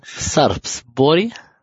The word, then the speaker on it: Sarpsborg: beide Tore beim 3 zu 3 gegen Sarpsborg. Äh, das ist wahrscheinlich auch falsch ausgesprochen. – Sarpsborg. Genau.